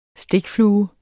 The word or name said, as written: stikflue